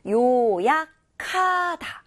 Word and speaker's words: 요약하다: 요약하다. 거센 소리가 나게끔 요약하다. 이렇게 읽었잖아요. 요약하다. 라고 읽는 겁니다. 왜냐하면 요약에서 기역과 하에서 히읗이 만나서 거센 소리를 내는 기역 발음으로 나는 거거든요. 그래서 요약하다. 이렇게 나는 거예요.